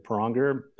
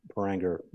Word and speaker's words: Pranger: It's, you know, my family actually pronounces it Pranger, which is more of a German pronunciation, but whatever's easiest for the audience or really anyone I'm dealing with. So most people, you know, use the Americanized version, which is Pranger, which is okay with me.